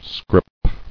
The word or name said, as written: Scrip